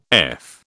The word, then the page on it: F: British English